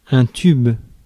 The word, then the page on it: pipe: American English; [pɑɪp]; British English; /pʌɪp/; /paɪp/